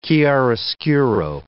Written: chiaroscuro

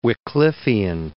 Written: Wycliffian

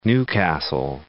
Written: Newcastle

